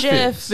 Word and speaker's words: GIF: GIF because... you pronounce the G just like you do in graphics, right? – Yeah.